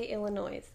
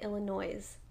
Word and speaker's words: Illinois: So, and I also think we're pronouncing it Illinois. It's just Illinois. – Illinois. – Whatever. Moving on. – Silent.